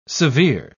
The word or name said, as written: severe